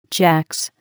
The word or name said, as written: jacks